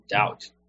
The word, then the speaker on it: doubt: Doubt 这个词很有趣，它的 b 不发音，读作 doubt，意思是 feel uncertain about，怀疑、不确定。